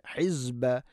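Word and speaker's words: ഹിസ്ബ (حزب): ഇന്ന നൂൻ ഷദ്ദ്, ഹിസ്ബ ഹിസ്ബ അല്ല, സീനല്ല, സായാണ്.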